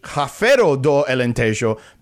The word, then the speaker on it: Rafeiro do Alentejo: It could be Rafeiro do Alentejo because the R in Portuguese is usually pronounced like an H.